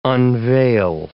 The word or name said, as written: unveil